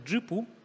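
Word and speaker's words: Zhipu: Я сегодня уже эту компанию рекламировала, она читается как Джипу.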